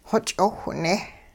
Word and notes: xoch'owhne: hupa

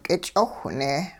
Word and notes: k'ich'owhne: hupa

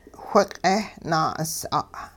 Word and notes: whiq'eh-naa'us'a': hupa

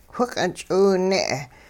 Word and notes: whiq'eh-ch'iwne': hupa

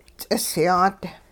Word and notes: ts'isyaat: Hupa

